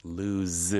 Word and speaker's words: lose: 잃어버리다는 lose, lose. – z 발음이죠.